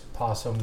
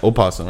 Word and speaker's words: opossum: The U.S. possum, when it's spelled with an O. – Yeah, opossum, right? – Yeah, but we just say possum, we don't say opossum, possum.